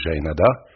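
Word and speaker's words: Jaenada: Mais quand on me demande la manière de le prononcer, moi je dis Jaénada,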